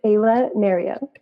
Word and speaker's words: Ayla Nereo: It's Ayla Nereo.